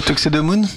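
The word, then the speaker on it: Tuxedomoon: Tuxedemoon. – Tuxedemoon.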